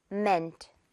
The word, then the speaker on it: meant: 자, 두 번째로 이것도 mint 아니겠죠? Meant가 되겠습니다. 듣고 따라해주세요. Meant.